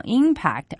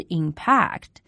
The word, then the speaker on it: impact: 那么这个词大家需要注意一下它的重读音节是在第一个音节上 ,impact, 而不是 impact。